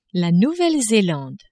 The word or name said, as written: Nouvelle-Zelande